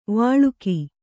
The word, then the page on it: ವಳಕು: kannada